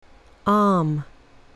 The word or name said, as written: arm